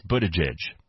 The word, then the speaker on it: Buttigieg: His name is Mayor Pete Buttigieg.